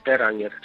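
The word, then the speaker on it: Per Anger: Peranger perdone que yo no lo pronuncie bien en sueco. – No, es correcto, es correcto, Peranger,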